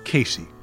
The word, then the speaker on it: Queysi: One of the tunes of Kerry Thomas that you play today, it's spelled Q-U-E-Y-S-I, but it's pronounced Casey.